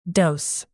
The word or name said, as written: dose